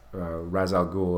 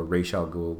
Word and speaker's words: Ra's al Ghul: But I'm reminded of this bit in Batman, one of the Batman comics, where Ra's al Ghul or Ra's al Ghul, whichever way you want to pronounce it, dorks.